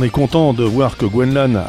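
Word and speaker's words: Gwenc'hlan: Alors attention, ça pourrait se prononcer différemment parce qu'il y a un C-H apostrophe. Gwenlan.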